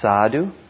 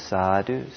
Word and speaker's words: sadhu: And it's pronounced sadhu, and it's usually said three times, sadhu, sadhu, sadhu.